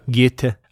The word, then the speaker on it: Goethe: And this was written in 1774 by a young Goethe. I think that's how you say his name. – Goethe.